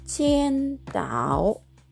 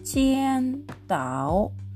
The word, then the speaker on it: 青岛: チンタオビールのチンタオは、チェンダオというふうに発音します。チェンダオ。これはあの青い島と書きますね。青いはチェンという発音です。